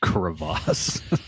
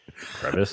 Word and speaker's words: crevasse: Crevasse. – That's how you say it. Crevasse. – Crevice. – It's not a crevice.